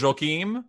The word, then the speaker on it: Joaquim: How do you pronounce Joaquim? – Joaquim.